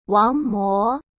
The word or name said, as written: wangmo